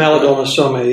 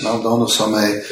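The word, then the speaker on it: Malidoma Somé: Maldoma Somme, yes. – Maldoma Somme.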